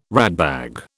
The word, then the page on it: ratbag: British English